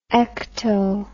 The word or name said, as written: ecto